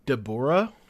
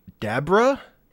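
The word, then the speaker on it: Dabura: They say Deborah. – Deborah. – But it's actually Dabra, as in Kadabra.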